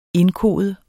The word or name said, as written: indkode